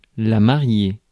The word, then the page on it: mariée: /ma.ʁje/; France